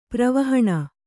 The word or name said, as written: ಪೂರ್ವಾಹ್ಣ